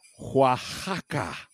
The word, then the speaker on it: Oaxaca: It's pronounced Oaxaca.